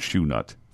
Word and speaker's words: Chouxnut: French shoe nut, C-H-O-U-X-N-U-T, Sunday, Bridgman's Ice Cream, said it's pronounced shoe nut.